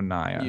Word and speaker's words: Naia: Actually, so it's a it's a long I. So Naia.